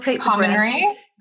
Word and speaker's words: Commonry: Commonry. – Commonry. So that's C-O-M-M. How do you spell that? – O-N-R-Y.